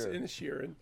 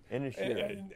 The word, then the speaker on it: Inisherin: It's Inisherin. – Inisherin.